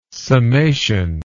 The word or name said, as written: summation